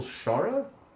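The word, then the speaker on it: Shaara: This week on Required Reading, this episode on Required Reading, we're doing Michael Schara. Is that how you say it? – I believe that is, yes.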